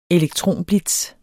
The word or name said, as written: elektronblitz